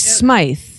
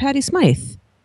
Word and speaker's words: Smythe: No, Patti Smythe and Patti Smythe. Oh, they're calling her Patti Smith. No, she's Patti Smythe.